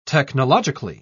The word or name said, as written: technologically